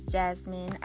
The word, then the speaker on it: Jasmine: My name is Jasmine.